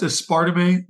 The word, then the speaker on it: aspartame: Aspartame? No, it's aspartame.